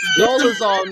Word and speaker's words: Dolezal: Rachel Dolezal. – Dolezal, nigga. Dole, like the fucking fruit cup.